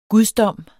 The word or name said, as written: gudsdom